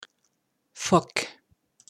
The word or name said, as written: Phoque